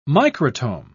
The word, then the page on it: microtome: British English